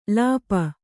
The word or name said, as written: ಲೇಪು